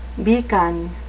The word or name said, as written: beacon